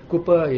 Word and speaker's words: Cowper: Cooper is C-O-W-P-E-R, not Cowper, as it's pronounced Cooper.